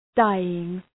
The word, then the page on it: dying: ‘daııŋ